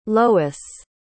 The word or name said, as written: lois